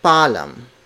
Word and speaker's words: paalam: In Filipino, we do not combine double vowels. Each vowel is pronounced separately. So it's paalam, not paalam.